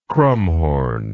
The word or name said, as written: krumhorn